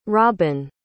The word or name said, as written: robyn